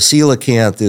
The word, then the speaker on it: coelacanth: Yeah, the coelacanth is kind of the rock star of the living fossil world. – Okay. – So the coelacanth lived during the Cretaceous period, allegedly. That's where we first find coelacanth fossils.